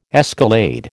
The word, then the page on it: escalade: American English